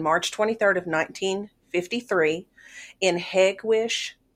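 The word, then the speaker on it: Hegewisch: Now, according to Wikipedia, which is where I usually like to take a lot of this information, is place locations, it is pronounced Hagwish by the locals.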